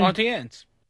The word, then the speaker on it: Auntie Anne's: And I keep calling them Aunt Annie's pretzels. Rosenberg, what do you call it? What's your pronunciation? – Auntie Anne's.